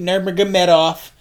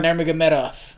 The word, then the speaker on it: Nurmagomedov: Conor McGregor is fucking back fighting Khabib Nurmagomedov.